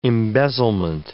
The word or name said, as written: embezzlement